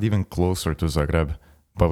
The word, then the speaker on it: Zagreb: Zagreb, yeah. Say that again, please. – Zagreb? Did I pronounce it wrong? – A bit, yeah. Zagreb.